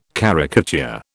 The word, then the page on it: caricature: British English